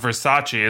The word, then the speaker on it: Versace: So it's like, that was really the lesson that I wanted somebody to teach her, as opposed to like, it's pronounced Versace, as they eventually do.